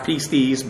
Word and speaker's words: pistis: Again, I know this is actually pronounced pistis, but most people have heard it so long. That way, I'm going to still say it that way.